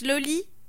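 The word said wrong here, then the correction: Lolë: L'olay. – L'olay. – Ou l'olé. – D'accord. – Bon, alors ça s'écrit L-O-L-E tréma, euh, si vous vous demandiez.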